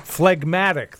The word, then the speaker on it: phlegmatic: I don't know why the silent G is there. Phlegmatic. That's why it's there, because phlegm came from phlegmatic.